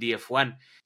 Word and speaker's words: Diefuan: Pero me di cuenta que DF1 no me gustaba cómo sonaba, entonces dije, bueno, pues estoy en clases de inglés, veamos cómo suena esto si lo pronuncio en inglés, ¿no? Entonces quedó la D como D, la F como F y el 1 como one. Y entonces así quedó el DF1. Simplemente lo escribí como se pronunciaría en inglés.